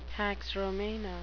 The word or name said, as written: pax romana